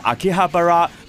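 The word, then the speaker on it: 秋葉原: 而秋叶原日文嘅发音就系 Akihabara，不过由于太长嘅关系啦，当地人通常都叫佢做秋叶，亦即系 Akiba。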